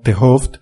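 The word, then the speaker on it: 't Hooft: Gerard Hoft que es un nombre muy complicado de pronunciar en holandés. Eh, si no les importa, mm, voy a referirme a él a partir de ahora como Tehoft, que es más, eh, más sencillo para un hispanohablante eh, pronunciarlo así.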